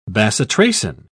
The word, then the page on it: bacitracin: British English